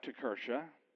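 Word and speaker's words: Kirche: So the kalika became this next word, which is Tekirche. I know it doesn't look like Tekirche, but that's the way it's pronounced, Tekirche.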